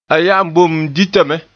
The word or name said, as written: ya'an